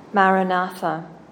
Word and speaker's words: Maranatha: Otherwise, we recommend the repetition of the prayer phrase Maranatha, said as four syllables of equal length.